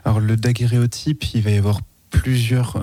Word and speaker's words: daguerréotype: alors du coup j'ai peut-être mal prononcé tout à l'heure. – Non, c'est ça, daguerréotype.